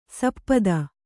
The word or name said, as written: ಸಪ್ತಿ